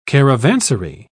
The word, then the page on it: caravansary: British English